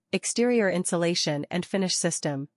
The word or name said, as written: EIFS Exterior Insulation and Finish System